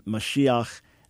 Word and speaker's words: Mashiach: So, yeah, Moshiach is not a play on Mashiach. Again, different vowels at the beginning, one with the long O, the Mo, the other with an A sound, and then a very different final letter.